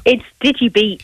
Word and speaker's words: DigiBete: Is it DigiBete? How do I pronounce it? – It's DigiBete.